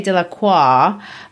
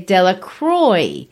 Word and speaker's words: Delacroix: If you want to say they mispronounce it, if you're French, they don't say Delacroix like the French might, or, that's my version of how the French might say it. They say Delacroix.